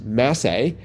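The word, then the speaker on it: massé: A Massey shot, pronounced Massey, is a shot typically done to curve the cue ball in an arc instead of a straight line.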